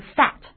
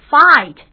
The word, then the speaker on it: fat: 那么注意这个 fat 这个发音啊，它是单元音，所以发的时候它的口型要固定不变啊，不要再说 fight 啊，那就成了双元音了，大家要注意。